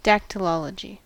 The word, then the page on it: dactylology: /ˌdæktɪlˈɒlədʒi/; /ˌdæktəlˈɑlədʒi/